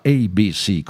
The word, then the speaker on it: ABC: ABC, il nome della band, si pronuncia ABC con The Look of Love.